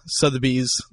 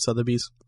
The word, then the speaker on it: Sotheby's: All the hosts were holding gloves because I guess Sotheby's, that's how you pronounce it, right? Sotheby's? – Yep.